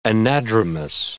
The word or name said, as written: anadromous